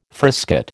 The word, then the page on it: frisket: American English